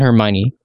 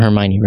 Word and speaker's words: Hermione: So Harry Potter is a witch who has recently enrolled in Hogwarts High School and he just met Hermione. And he has a crush on Hermione, right?